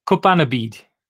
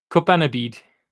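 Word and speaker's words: cwpanaid: Hefenavid. – Not even close. It's Kupanabid.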